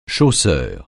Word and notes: chausseur: français

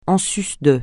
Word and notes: en sus de: français

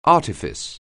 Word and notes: artifice: anglais

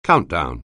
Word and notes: countdown: anglais

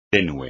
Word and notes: tenue: espagnol

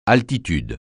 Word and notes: altitude: francais

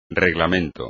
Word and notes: reglamento: espagnol

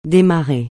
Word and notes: démarrer: français

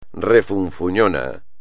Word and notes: refunfuñón: espagnol

